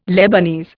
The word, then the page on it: Lebanese: Indian